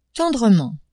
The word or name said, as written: tendrement